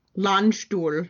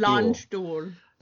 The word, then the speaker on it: Landstuhl: I'm saying that right? You know how to speak. – Landstuhl. – Long stool. – Landstuhl. – Sounds like long stool to me.